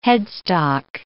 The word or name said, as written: headstock